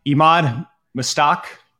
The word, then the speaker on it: Emad Mostaque: All right, Emad Mostaque.